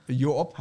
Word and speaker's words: Joop: Joop heißt der, das Rote von Joop. Joop. Nee, Joop heißt der, ne? – Achso, ja, okay. – Falsche Betonung.